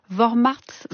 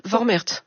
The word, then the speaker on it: Vormärz: Vous avez donné le terme allemand, c'est Vormärz. – Vormärz. – Vormärz. – Voilà. – C'est ça, je le dis bien.